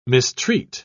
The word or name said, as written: mistreat